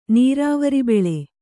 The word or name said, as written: ನೀರಾವರಿಬೆಳೆ